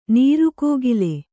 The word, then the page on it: ನೀರುಕೋಗಿಲೆ: nīru kōgile